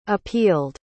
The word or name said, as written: appealed